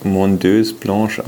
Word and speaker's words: Mondeuse Blanche: Und das andere ist vermutlich Mondeuse Blanche ausgesprochen.